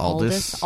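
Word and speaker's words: Aldis: Is it Aldous or Eldis? – I think Aldous.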